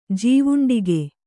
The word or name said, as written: ಜೀವುಂಡಿಗೆ